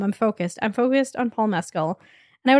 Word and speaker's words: Mescal: I do know that his name is pronounced Paul Mescal.